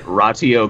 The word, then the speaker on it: Ratio Christi: Is it Ratio Christi? I don't know. – I mean, it's ratio. Ratio, yeah.